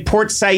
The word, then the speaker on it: Port Said: You live in, you say, Port Said, Egypt. And what do you do? – Well, actually, that's a very good pronunciation for it.